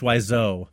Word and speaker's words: Wiseau: How have you pronounced his name? – I think it's Wiseau. – Wiseau, okay.